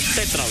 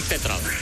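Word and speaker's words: Tetreault: Y bueno, pues me dio la pronunciación como Tetral, como si le pusieras acento en la E, porque se escribe Tetraul, con T al final, Tetraul. Pero entonces, pues supuestamente es Jackson Tetral, la forma en que se pronuncia este apellido de origen francés.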